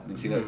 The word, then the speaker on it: Minccino: Mincino? – Yeah. – The, yeah. – Do you have it spelled out? – M-I-N-C-C-I-N-O. Mincino.